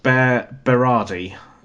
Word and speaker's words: Berardi: Berardi.